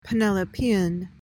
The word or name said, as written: penelopean